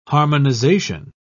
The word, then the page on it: harmonisation: British English